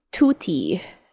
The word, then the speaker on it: tutti: Okay, next word is tutti. It's spelled T-U-T-T-I. Correct or incorrect? – Correct. – Okay, it's tutti. Tutti means all together.